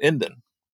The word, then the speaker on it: NDN: And the way I've always heard it said is like there's no A in the word. You know, instead of saying Indian, like for someone from India, you pronounce it Indan, like there's no A in the word. And online, you will see them actually using the three letters, N, D, N.